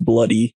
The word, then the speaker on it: Bluidy: And because of how poorly the Covenanters were treated while in this prison, Sir George Mackenzie earned the nickname Bloody Mackenzie, which the Scottish spelling is B-L-U-I-D-Y. But it's just pronounced Bloody Mackenzie.